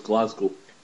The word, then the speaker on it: Glasgow: Okay, so it's Glasgow. – Glasgow. – Yeah. – Not Gal. – No, Glasgow. – Okay. Glasgow. – Glasgow. And the S is kind of like a Z, Glasgow.